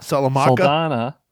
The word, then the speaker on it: Saldana: So on the person's name that I could not pronounce, Zoe Salamaca? – Saldana.